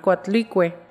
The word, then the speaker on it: Coatlicue: Coatlice, siempre lo digo mal, Coatlicue.